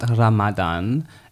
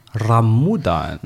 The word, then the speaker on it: Ramadan: According to Google and a few of my sources, it's not Ramadan, it's Ramudan.